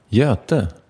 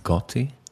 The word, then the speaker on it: Göte: Det är jättesvårt i vissa länder att uttala Göte. – Gati? – Ja, det blir mycket Göte. Och vi har hört alla olika.